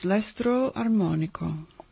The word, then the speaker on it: L'estro armonico: That's L'estro armonico. Is that how you pronounce that? – Let me see. – Yes, L'estro armonico.